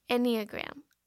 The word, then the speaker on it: Enneagram: Ooh, Enneagram. I'm going to spell it because it's a weird word. E-N-N-E-A-G-R-A-M. – It's like Instagram, but Ennea.